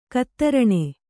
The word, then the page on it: ಗೋದಾರಣ: gōdāraṇa